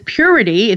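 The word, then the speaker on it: Pureti: And we'd like to know, with the new technology in photocatalytic coatings, what is the pronounced as purity? It's P-U-R-E-T-I.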